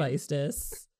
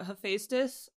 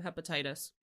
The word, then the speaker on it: Hephaestus: No, but Hephaestus, I used to always call him Hepatitis because I was just reading it in my head and I didn't know how to pronounce it.